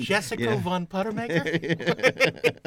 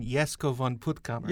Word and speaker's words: Jesko von Puttkamer: And, Jessica von Puttemaker? – I'm a big fan of his. – I'm sure you mean Jesko von Puttkamer.